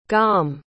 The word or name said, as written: gaum